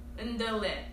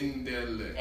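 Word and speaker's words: ndolé: But the national dish, like the number one national dish, I hope I pronounced this right, ndole. I don't know, but it's ndole. – It's spelled N-D-O-L-E, but the E has an hyphen on it.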